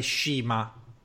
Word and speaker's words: Cima: Poi c'è stato Giovanni Kenny Omega contro Cima, che in realtà si dovrebbe pronunciare Shima, però sì.